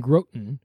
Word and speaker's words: Groton: As I said, his name was Valentine Whiteman, and he had started that church in Groton, I believe that's how you pronounce it, G-R-O-T-O-N, Groton, Connecticut.